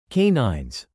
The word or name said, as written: canines